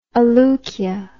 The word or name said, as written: aleukia